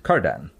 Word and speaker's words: kerdan: How do you spell that? – K-E-R-D-A-N. – And you pronounce it... – Kardan. Or kerdan.